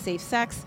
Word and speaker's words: SFSX: If you're looking for this book, there are no vowels, so it's SFSX, but it is pronounced Safe Sex.